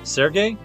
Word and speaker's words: Serge: Sergei or Serge? – Serge, I think.